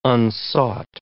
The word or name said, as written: unsought